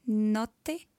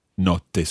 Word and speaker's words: notte: Notte. – Notte. So just watch your vowel sounds in there.